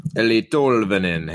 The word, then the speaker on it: Tolvanen: Eli Tolvanen. Hey, I got out my Finnish pronunciation for that kid.